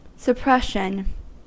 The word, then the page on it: suppression: American English